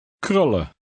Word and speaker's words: krullen: Remember to continue to drop the N in the EN ending. Read number eight. – Krullen.